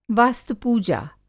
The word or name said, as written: वास्तु-पूजा